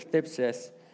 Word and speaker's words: thlipsis: This word tribulation is it's a Greek word that's thlipsis. It's hard to pronounce, especially with the Invisalign, but it's thlipsis.